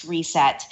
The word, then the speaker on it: R3SET: They are spelled R3SET, but pronounced Reset.